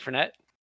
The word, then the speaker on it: Fournette: Leonard Fournette? – Yep. – Yeah. Yes, that is actually how you say it.